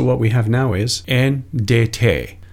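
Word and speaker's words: en (ἐν): The very first word is on, and on is Greek number 1722, and the Greek pronunciation is en.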